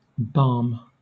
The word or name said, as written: barm